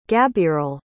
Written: gabirel

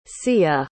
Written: sia

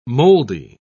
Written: moldy

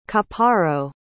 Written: chaparro

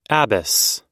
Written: Abbas

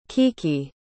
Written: kiki

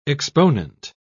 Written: exponent

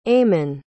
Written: ayman

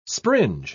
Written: springe

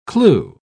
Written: clew

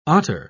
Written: otter

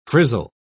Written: frizzle

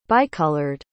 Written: bicolored

